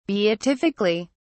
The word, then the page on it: beatifically: British English